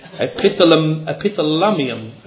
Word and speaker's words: epithalamium: Epithalamium.